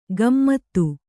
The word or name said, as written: ಗಮ್ಮತ್ತು